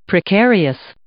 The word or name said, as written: precarious